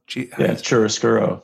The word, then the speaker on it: chiaroscuro: Chiaroscuro. – Chiaroscuro.